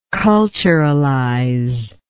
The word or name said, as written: culturalization